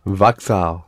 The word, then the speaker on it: Vauxhall: The word that you have there is Vauxhall. How did we pronounce it? – Vauxhall.